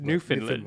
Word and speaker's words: Newfoundland: And there were 7,000 passengers stranded in a tiny little Canadian town, Newfound- Newfoundland. – Mm-hmm. – I know it's spelled Newfoundland. – It is, but it's Newfoundland.